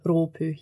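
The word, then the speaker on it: Rōpū: Sorry, my girlfriend always tells me pronounce it correctly. oh, Ropu, yeah.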